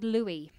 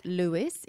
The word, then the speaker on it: Louis: It's not Louis. It's Louis as in Louis Armstrong and Louis Tomlinson from One Direction.